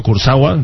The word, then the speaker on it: Kurzawa: El PSG, el Paris Saint-Germain, va a comprar al Mónaco a su lateral izquierdo, Kurzawa, creo que lo he pronunciado bien.